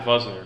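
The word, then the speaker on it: Wosner: So, pronounces it Vuzner because he's Hungarian. I think Hungarian.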